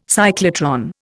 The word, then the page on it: cyclotron: Indian